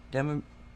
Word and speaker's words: demiurge: And when he finally came back, he said, oh, well, the demiurge, did I say that right? – Demiurge. Imagine that you have an urge for ice cream, but you only want half the amount, not the full thing. So instead of an urge, you have a demiurge.